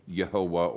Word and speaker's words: Yehovah: If you look even of how God forms the foundation for many of people's names, uh, it's one of the proofs that there's three syllables, not two. Yahweh is two syllables, Yehovah or Jehovah is a 3, and that occurs 6,877 times.